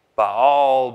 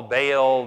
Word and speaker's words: Baal: And it's Baal, Baal, there's all sorts of different ways to say it, but it's spelled B-A-A-L.